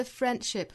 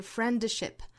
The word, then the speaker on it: friendship: 后面的 friendship 注意在单词之中的这个 d 的音不发出来，不是 friendship，而是读成 friendship.